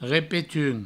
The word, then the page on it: repetums: repetun; Provençal